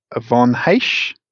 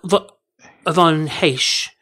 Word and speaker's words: Ivan Reis: And I believe it's, to be nerdy, Von Heisch is the pronunciation of Ivan Reis. – Oh, Von Heisch.